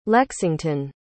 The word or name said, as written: lexington